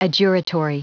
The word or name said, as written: adjuratory